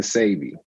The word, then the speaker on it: Sebi: Because I heard Sebi, Sabi, Sebi. Which one is correct? – It's really Dr. Sabi.